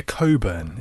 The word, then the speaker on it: Cockburn: Olivia Coburn. Isn't that how you pronounce Cockburn? – I'm fairly sure it's Cockburn.